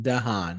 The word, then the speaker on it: DeHaan: Dane DeHaan, yeah.